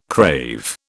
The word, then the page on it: crave: British English